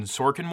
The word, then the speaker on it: Sorkin: Aaron Sorkin. Was it? Yep. Okay. Did I pronounce his name right? – Yeah.